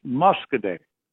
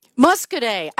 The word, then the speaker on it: Muskoday: That's pronounced Muskoday. – Muskoday. I get this wrong every time. You keep correcting me, and one of these days I'll get Muskoday right.